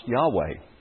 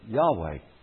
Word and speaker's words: Yahweh: Those four letters are pronounced Yahweh, in all likelihood, Yahweh.